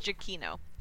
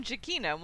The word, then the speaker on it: Giacchino: Well, how do you not know that it's pronounced Giacchino? – Because I've heard him pronounce his name. – I've heard him pronounce his name Giacchino multiple times. – Holy shit, he pronounces his name wrong.